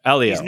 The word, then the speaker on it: Elio: So no H. Elio.